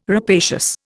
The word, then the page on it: rapacious: Indian